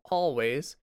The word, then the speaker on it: Alvvays: The musical recommendation for this episode is a band called Always, but it's spelled with two V's instead of a W. So A-L-V-V-A-Y-S.